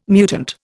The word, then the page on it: mutant: Indian